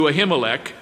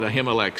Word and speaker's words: Ahimelech: Then came David to Nob, to Ahimelech the priest. In fact, in the Hebrew, the word is pronounced Ahimelech, but we'll call it Ahimelech since that's what we're used to.